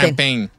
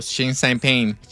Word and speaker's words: Xi Jinping: Xi Jinping. – ¿Champagne? – No, Xi Jinping. – Xi Jinping. – Pain. – Xi Jinping. Uh-huh. Bueno. El presidente Donald Trump y el presidente chino, Xi Jinping.